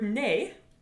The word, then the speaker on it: nez: So the word nez, which means nose, is spelled N-E-Z. And so you restore the silent Z and then you add an E on the end.